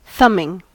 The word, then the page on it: thumbing: American English